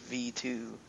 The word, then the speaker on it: Megadimension Neptunia VII: Megadimension Neptunia V2, even though it's spelt out, or it's written like it says 7, but it's pronounced V2.